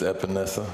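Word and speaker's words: Epenesa: Pronounce his last name, because I can't. – It's Epinesa.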